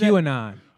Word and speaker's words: QAnon: It's like you know how he talked about that the president disavowed that Kunan. No, he didn't disavow. Is it Kunan? How you pronounce that? Kunan or Kunanin or what was Q-anon. – That? QAnon. Okay, there's something to that, though.